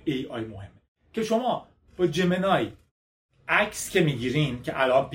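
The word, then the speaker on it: Gemini: الانم گوگل این جمینی ما بهش میگفتیم جمینای، تلفظ درست‌تریه بگم عادت کنیم بگیم جمینای، بعد نیست تلفظ جهانی رو بگیم.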